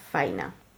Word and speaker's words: Faina: Lo que sí sabemos con certeza es que Andrei se acabó casando en 1963 con una amiga de su hermana llamada Faina. Que se pronuncia como más como Fiona, pero se escribe como Fallina.